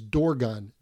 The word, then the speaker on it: Dorgon: You see him pronounced in Western text as Dorgon, D-O-R-G-O-N,